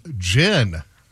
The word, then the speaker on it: Jyn: Mine was from Jen. – Oh. – What a cool name. J-Y-N, pronounced Jen as in the drink.